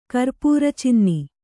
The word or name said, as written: ಕರ್ಪುರಚಿನ್ನಿ